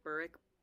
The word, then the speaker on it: Burrich: Well, I never heard of it pronounced Burrick until someone posted on Instagram a video and was like, here's how different countries pronounce, like, the, like, Realm of the Elderlings names. And I think that, like, the UK pronounces it Burrick, and the US pronounces it Burridge.